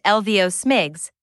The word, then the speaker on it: LVOSMGCS: Advisory Circular 120 to 57, Low Visibility Operations Surface Movement Guidance and Control System, commonly known as LVOSMGCS, pronounced LVO SMIGS,